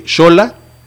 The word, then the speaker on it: Xola: O sea, puedes decir Xola, que es se escribe con X, pero se pronuncia como CH.